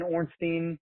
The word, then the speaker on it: Ornstein: My name is Ben Ornstein, uh, O-R-N-S-T-E-I-N. My last name is how you spell it.